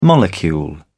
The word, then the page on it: molecule: British English